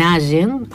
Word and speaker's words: Allen: Yo nací en Allen, así se pronuncia,